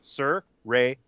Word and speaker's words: Saraya: And it's Saraya. Saraya. That, it's not Saraya. It's not Saraya. It's Saraya. Anyway, around the horn, let's start with our guest, Mr. Cook.